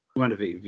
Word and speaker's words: Guinevere: Now she's with her friend, uh, what is it, Guinevere? Guinevere, yep.